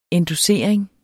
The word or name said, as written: endossering